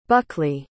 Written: buckley

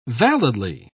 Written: validly

